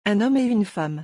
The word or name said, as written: un homme et une femme